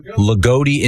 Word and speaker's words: Loogootee: L-O-O-G-O-O-T-E-E. – Lagodi? – Lagodi, Indiana.